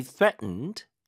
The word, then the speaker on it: threatened: They threatened TH 발음 to kick me out.